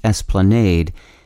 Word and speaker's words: esplanade: Today's word is esplanade. It's also sometimes pronounced esplanade and spelled E-S-P-L-A-N-A-D-E.